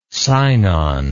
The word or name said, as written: Sinon